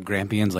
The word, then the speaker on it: Grampians: It's been spotted all over the place repeatedly in the Grampians. Grampians? I don't know how to say that correctly, Australians. I'm sorry. But since the 1970s. – Are Grampians like geriatric champions? – Yes, just like that.